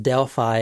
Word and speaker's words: Delphi: Yes, it's pronounced Delphi and not Delphi.